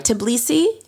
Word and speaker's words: Tbilisi: You had over 1,000 people come here to, am I saying it right, Tbilisi? – Yeah.